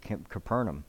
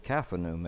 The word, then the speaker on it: Capernaum: It says, "And they went into Capernaum, and immediately," before I get started, when I always pronounce it Capernaum. And then I was listening to the Strong Concordance, and it's Capernaum. And I was like, all these years I had it wrong.